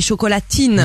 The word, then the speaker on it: chocolatine: qu'entre notre accent et les D en Autriche qu'on prononce T en Autriche, on n'entendait pas chocoladine mais chocolatine.